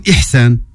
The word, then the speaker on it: Ihsan: Qui se prononce Ihsan.